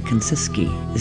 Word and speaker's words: Kuncicky: K-U-N-C-I-C-K-Y, Kanciski is how you say it.